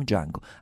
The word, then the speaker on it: Django: Quanti hanno visto il film Django? Ancor meglio, perché ne parleremo questa mattina. – Tequila. Come ti chiami? – Django. – Sai come si scrive? – D-J-A-N-G-O. La T è muta.